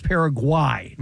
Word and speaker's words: Paraguay: So its proper pronunciation is Paraguay, not Guay.